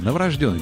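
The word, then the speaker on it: новорождённый: Новорожденный, буква Йо. Буква Йо. Но она всегда везде ударная. Новорожденный. Хотя новорожденный в словарях помечен как устаревший вариант, как будто бы, но правильно говорить, действительно, новорожденный.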